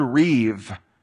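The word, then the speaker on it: riv (ריב): It's the Hebrew word riv. It's spelled rib, but pronounced riv.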